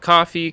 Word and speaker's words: Ko-fi: Coffee. I say coffee, yeah. – Ko-Fi. – Because the logo's a little coffee cup. – Cup of coffee, right? Yeah. I think it's coffee.